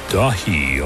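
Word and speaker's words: Daithi: album from Neil Young called Dahi, although it's spelled D-A-I-T-H-I. I'm pretty sure it's in Gaelic.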